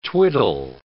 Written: twiddle

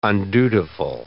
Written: undutiful

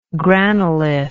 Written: granolith

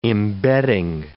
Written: embedding

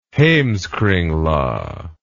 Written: heimskringla

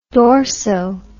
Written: dorso

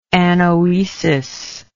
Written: anoetic

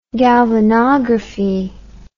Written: galvanography

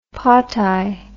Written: potae

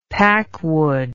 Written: packwood

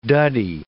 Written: duddy

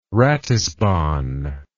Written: ratisbon

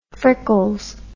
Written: frickles

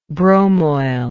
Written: bromoil

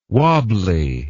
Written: wabbly

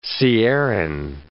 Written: sierran

